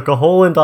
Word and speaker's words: Koholint: And I just want to point out, you added a few extra syllables to Koholint Island.